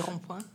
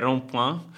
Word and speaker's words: rond-point: C'era proprio questa figura della rotonda, ora magari in francese è meglio se lo dici te perché io la pronuncia Le rond-point. – Le rond-point.